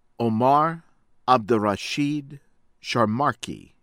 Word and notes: Omar Abdirashid Sharmarke: American English

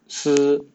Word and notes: 私: Mandarin Chinese

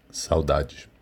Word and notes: Saudade: ptbr

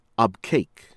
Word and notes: Abqaiq: from Saudi Arabia

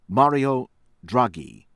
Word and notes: Mario Draghi: American English

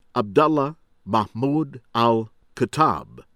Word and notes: Abdullah Mahmoud Al-Khattab: American English

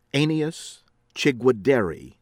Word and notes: Aeneas Chigwedere: American English

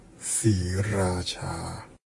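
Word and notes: Sriracha: Thai

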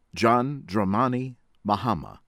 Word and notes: John Dramani Mahama: American English

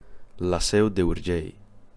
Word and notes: La Seu d'Urgell: Catalan

